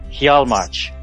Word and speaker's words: Hjalmarch: Is that a hard J? – I believe it's a soft J. Hjalmarch.